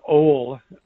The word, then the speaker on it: Oail: His dad, he was a junior, so his first name is Oail, O-A-I-L, which nobody can pronounce. – Oail. – I still can't. O-A-I-L.